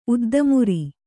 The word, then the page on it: ಉದ್ದಮುರಿ: kannada